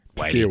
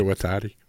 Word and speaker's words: Waititi: It's adapted from the cult movie by Taika Waititi. Did I say it right? – Close enough. – Waititi. – Waititi.